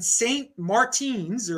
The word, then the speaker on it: St. Martin: But apparently Roger Ver has convinced some guy in St. Martin's, or I can't pronounce it. – It's St. Martin.